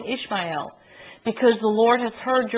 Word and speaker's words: Ishmael: It should be pronounced Ishmael, but you'll hear me say Ishmael. So I might slip back and forth. If Jeff Griffiths were here, it would be Ishmael. He would say it right.